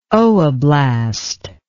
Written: ooblast

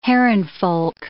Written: herrenvolk

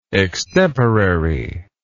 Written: extemporariness

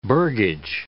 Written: burgage

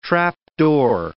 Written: trapdoor

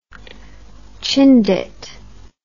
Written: chindit

